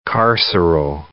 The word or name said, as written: carceral